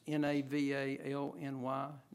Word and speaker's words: Navalny: I hope I'm pronouncing his last name right. It's N A V A L N Y, Navalny.